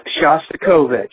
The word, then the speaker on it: Shostakovich: And I believe the way you pronounce the name of the composer that you mentioned is Shostakovich.